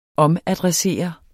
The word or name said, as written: OMADRESSEREDE